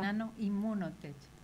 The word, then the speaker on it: Nanoimmunotech: porque además es fue directora del Centro de Investigación Biomédicas, del SIMBIO, durante 10 años, eh, copromotora de la empresa spin-off Nanoimmunotech, está bien pronunciado África. Nanoimmunotech. ¿Ves? Ya decía yo, es que está muy complicado.